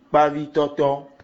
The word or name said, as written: poisson frit